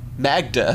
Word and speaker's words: Magda: And Magneto's like, oh, Magna, we have to forget the past. – Magda. – How do you say it? – That's how you say it. – Can you spell it? – I think it's M-A-G-D-A. – Magda. Magnus and Magda.